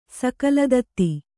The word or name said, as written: ಸಕಲದತ್ತಿ